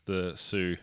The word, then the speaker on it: Sioux: The frontiersmen think the Chinese are Jews, while the white settlers think it's the Sioux who are. Is that how you pronounce that tribe? S-I-O-U-X? Yeah, the Sioux. – You nailed it.